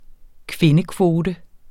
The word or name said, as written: kvindekvote